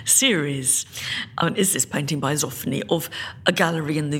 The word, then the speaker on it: Johann Zoffany: Zoffany. – Zoffany. – Johann Zoffany.